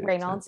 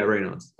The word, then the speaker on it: Raynaud's: Like my father, who's a doctor, thought when he saw it, he thought it was this condition called idiopathic Raynaud's disease. I don't know how to say it. – Raynaud's, so. Yeah. Yeah, Raynaud's.